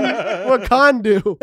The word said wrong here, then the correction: Wakanda: And eventually he came up to me afterwards. He's like, what'd you think of that sermon illustration? I go, I'll be honest, man. It's pronounced Wakanda.